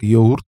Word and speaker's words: йогурт: Почему йогурт? Потому что это турецкое слово, оно произносится в турецком как йогурт. Там такой звук, который обозначается буквой «г» с немножко галочкой такой. Он как бы произносится, а с другой стороны не произносится. Он как выдыхание. Это что-то среднее между отсутствием звука и фрикативным «г».